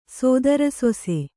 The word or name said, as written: ಸೋದರಸೊಸೆ